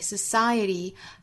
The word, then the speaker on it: society: Our tragedies don't occupy society. Society 这个词美音之中没有 er 的发音，很多同学这个词都会读错啊，就是在单词内部，尤其是在倒数第二个音节读出一个 r 的发音。